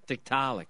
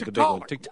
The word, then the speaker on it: Tiktaalik: Am I saying that right? – Tiktaalik, yeah. – Tiktaalik. I'm doing the accent on that. – I think that. – The da is the big, one. Tiktaalik.